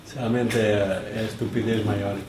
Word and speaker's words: estupidez: In Italian it's stupido, and here it's stupidez. – The person is stupido. – This person is stupido, yes, and the actions are stupidesh.